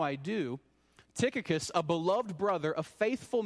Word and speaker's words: Tychicus: Well, one of those is the honorable Tychicus. Did I pronounce that right, Dave? All right, Dave's good with it.